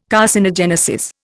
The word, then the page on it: carcinogenesis: Indian